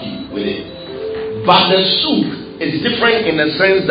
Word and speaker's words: suk: The word anoint in Deuteronomy chapter 28, verse 40 is the word suk. Everybody say suk. – Say suk. It's spelled S-U-K. But sometimes they also spell it S-O-O-K.